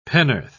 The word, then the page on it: penn'orth: British English